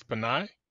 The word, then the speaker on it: Pinay: It's Pinay? – Exactly.